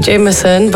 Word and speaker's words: JMSN: I think you pronounce it Jameson, but it's JMSN.